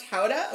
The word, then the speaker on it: Gouda: Because I had been saying Gouda, and she's like, oh. – You're American. – She goes, oh, it's pronounced Howda?